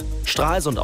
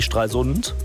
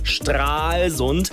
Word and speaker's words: Stralsund: Und falls Sie sowas nicht wissen, wie die Tatsache, dass Stralsund Stralsund ausgesprochen wird und nicht Stralsund, merken Sie sich einfach langes A, wie beim Aal, Stralsund, hätten wir noch mehr.